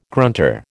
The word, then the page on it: grunter: American English